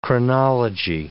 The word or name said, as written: chronology